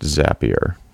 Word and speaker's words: Zapier: And those guys actually reached out and thanked us for talking about it and let us know that it's pronounced Zapier. – That makes sense. – It does. Does it? Cause it's like rapier with a Z.